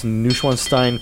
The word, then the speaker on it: Neuschwanstein: I think it's pronounced Neuschwanstein Castle. It's in southern Germany, Alpine Castle.